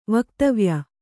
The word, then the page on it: ವಕ್ತವ್ಯ: vaktavya